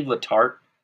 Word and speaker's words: Letarte: Was it Steve Letarte? Is that how you say his name? – Yeah.